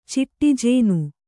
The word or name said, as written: ಚಿಟ್ಟಿಜೇನು